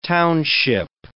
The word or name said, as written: township